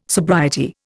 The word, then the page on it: sobriety: Indian